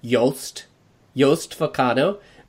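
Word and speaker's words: Jost Vacano: So his name would be pronounced Yost Vacano, which is really crazy.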